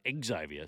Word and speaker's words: Xavier: He said it's Xavier, so there you go. You have to call him Xavier Savage.